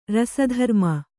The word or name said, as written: ರಸಧರ್ಮ